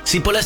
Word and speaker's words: Cipolla: Cipolla se escribe con C y con... de, con Elie, o sea, Cipolla. – Cipolla, ¿no? Cipolla. Cebolla en italiano.